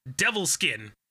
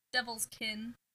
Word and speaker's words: Devil's Kin: I always want to say their name wrong. I have to, like, space it out in my mind. – No, it's spelled Devil's Skin. Like, Devil's Skin. Slur. – Like, foreskin. it's Devil's Skin. – Devil's Kin. – No, I know that's how it's said.